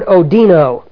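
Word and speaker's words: odino (ὠδίνω): The word that's used for labor is the word odino. Looks like this. Actually, it's a silent H. I'll put it there. H-O-D-I-N-O.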